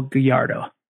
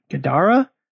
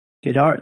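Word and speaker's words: Guidara: Number two, Unreasonable Hospitality by Will Gallardo. I think I mispronounced his name. Gadara? Gadara.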